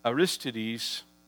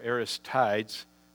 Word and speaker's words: Aristides: In the early church, the emperor Hadrian sent one Aristides, or Aristides, some people would pronounce it, to spy on a peculiar religious sect called Christians that were beginning to flourish in the Roman Empire.